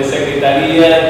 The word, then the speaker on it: secretaría: Puede haber una secretaría de salud, una secretaría de asuntos exteriores, una secretaría de defensa. Si tiene acento, es la pronunciación de secretaría y significa el departamento del gobierno.